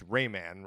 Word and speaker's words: Rayman: It's Rayman, right? – Rayman. It has Rayman. To be Rayman. – Right. Rhymes with Ray-Bans. – Yeah. – Sort of. It's a soft rhyme.